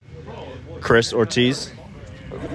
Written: Chris Ortiz

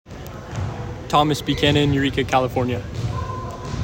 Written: Becannon Thomas

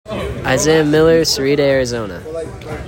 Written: Isaiah Miller

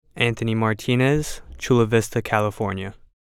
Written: Anthony Martinez

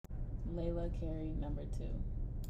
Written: Lailah Carey

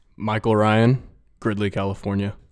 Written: Michael Ryan

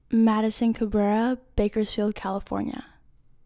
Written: Madison Cabrera

